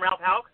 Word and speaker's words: Ralph Houk: Ralph Houk.